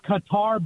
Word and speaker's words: Qatar: It's Qatar, but it's you got to put an H at the end of it. It's Qatar.